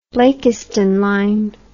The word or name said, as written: Blakiston line